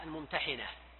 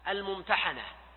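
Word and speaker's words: الممتحِنة: هذه السورة اسمها سورة الممتحنة، و تنطق بكسر الحاء الممتحنة، لأن هذا يكون وصف للسورة نفسها أنها السورة الممتحنة، يعني التي نزل فيها الامتحان، وهكذا عند الأكثرين. وبعضهم ينطقها بفتح الحاء الممتحنة، إشارة إلى المرأة الممتحنة، وأول امرأة وقع عليها الامتحان هي أم كلثوم بنت عقبة في القصة المعروفة.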